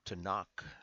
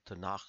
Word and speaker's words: Tanakh: Tanakh is what I often say, but I understand that I need to pronounce it a little softer on the end. Tanakh is the correct way.